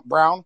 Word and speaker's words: Braun: Brown, I guess is how you pronounce his name.